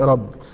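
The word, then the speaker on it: ربي (ῥαββί): هي مكتوبة بالحروف اليونانية بتتنطق ربي.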